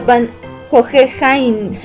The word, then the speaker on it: Van Hohenheim: El de Full Metal Alchemist se pronuncia Van Hohenheim.